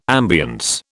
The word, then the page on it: ambience: British English